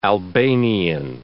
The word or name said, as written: Albanian